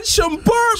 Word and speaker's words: Shumpert: Iman Shumpert, bro! Shumpert! – Tu prononces pas le T à la fin? – Non, Iman Shumpert! – Ok, Iman Shumpert. Ok, c'est beau, je le prononce plus.